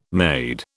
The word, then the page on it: maid: British English